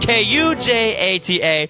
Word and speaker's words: Kujata: it should have been spelled K U J A T A and pronounced Kujata, like Mufasa.